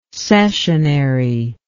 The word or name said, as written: cessionary